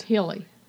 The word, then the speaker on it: Guli: And you're thinking the last name is pronounced? – Hilly. – But how's it spelled? – G-U-L-I.